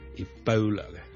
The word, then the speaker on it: Ebola: 最近咧，如果大家有睇國際新聞咧，都應該會留意到 Ebola 呢個伊波拉病毒咧，喺某啲地方肆虐嘅。 Ebola 伊波拉病毒咧，我哋雖然廣東話我哋譯做伊波拉波啦，我哋嘅 O 呢個發音，但如果英文咧，我哋通常咧係讀 Ebola 嘅 Ebola bow bow，好似弓箭嘅弓嗰個 bow。